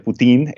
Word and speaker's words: poutine: Segundo, me gustaría compartirles un poco sobre el poutine o poutin con la pronunciación francesa, que es básicamente el plato nacional de Canadá.